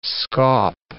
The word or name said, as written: scaup